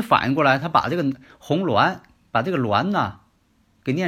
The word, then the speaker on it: 红鸾: 这个呢叫红鸾，无论是四柱当中，还有这个紫微斗数当中都有这个名词红鸾，不要把它念成红英啊，怎么念成红英呢？红鸾嘛。